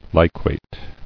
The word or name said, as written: Liquation